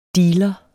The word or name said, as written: DEALEREN